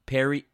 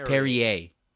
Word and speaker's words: Perrier: It's Perry. Well, I don't know if we said that, but it's Perrier. – Perrier. – So the R is silent at the end.